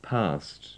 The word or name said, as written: past